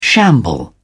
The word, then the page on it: shamble: British English